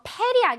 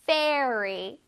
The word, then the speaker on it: fairy: 이거 페리 아닙니다. 페리 아니고 Fairy.